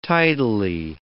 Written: tidily

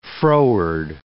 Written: froward

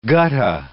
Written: gutta

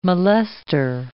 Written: molester

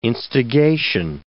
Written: instigation

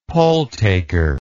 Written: polltaker

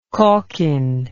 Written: calkin

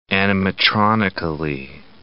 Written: animatronically

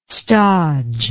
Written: stodge

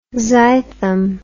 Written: zythum

